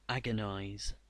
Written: agonize

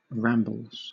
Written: rambles

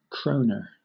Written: kroner

